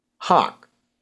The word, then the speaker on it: Haugh: H-A-U-G-H, General, how do you pronounce that? – He pronounces it Hawk, like the bird, Hawk. – Hawk. – Yeah. – Okay, I didn't know, I always thought the G-H was maybe silent.